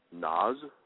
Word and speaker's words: Nas: I just wanted to say, I thought I wouldn't like the music, but I'm really liking that Nas guy. – Mm-hmm. – Am I saying that right? – Yeah, you are saying that right.